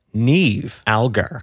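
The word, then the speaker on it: Niamh Algar: Her name is actually pronounced Neve Algar.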